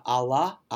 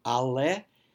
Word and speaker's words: alle: Alle, not Allah, alle.